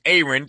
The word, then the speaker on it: Aaron: It's spelled like Aaron, A-A-R-O-N, pronounced Aaron. Tell me about that first name. – Man, that's my mama, brother. My mama called me Aaron, so that's my name.